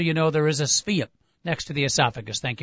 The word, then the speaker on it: Sveum: Might be snuggling up a little bit, but Dale, how do you pronounce the last name? Is it Sveum? – Sveum.